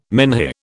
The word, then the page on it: menhir: British English